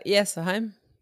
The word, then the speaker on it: Jessheim: Em 3 de fevereiro de 1990, o Mayhem fez o show de lançamento desse álbum em Jessheim, na Noruega. Falei certo, é Jessheim? – Uh, Jessheim. Jessheim.